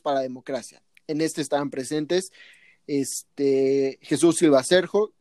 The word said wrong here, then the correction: Herzog: perdón, ¿cómo se, pronuncia? Jesús Silva, Herzog,